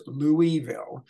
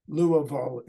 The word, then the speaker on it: Louisville: Another Studio Simon Stumper live from the studios of Studio Simon in Louisville, Kentucky.